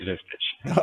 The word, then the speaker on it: Vistage: T-E-C. It's now called, is it pronounced Vistage or Vistage? – Yes. In this country, it's Vistage.